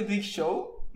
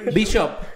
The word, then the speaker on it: Bishop: También Luis Vázquez, un gran saludo, nos manda 5 dólares y nos dice, cuando dijo el sacerdote Big Show, me lo Bishop.